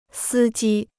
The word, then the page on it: 司机: sī jī